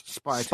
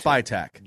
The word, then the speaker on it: Spytek: I would prefer that you say his name correctly. – John Spytek? What? – The name's Spytek. – Spytek. – John Spytek. – Spytek. Okay. Yeah.